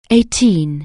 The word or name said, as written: eighteen